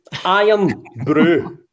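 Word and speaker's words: Irn-Bru: So, right, this is pronounced iron brew, right? Not iron brew, because any English people I speak to are like, are you drinking some iron brew? No, it's no iron brew. It's iron brew.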